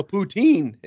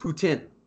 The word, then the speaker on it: poutine: There's no poutine in the States. – It's pronounced poutine.